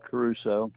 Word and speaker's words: Caruso: Yeah, that's Richard Caruso. – Could you spell that last name for me? – C-A-R-U-S-O.